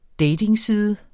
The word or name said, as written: datingside